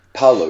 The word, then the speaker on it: Paolo: And when you were talking about that, I think, is he called, how do you pronounce his name again, Paolo? – Paolo, yeah, P-A-O-L-O.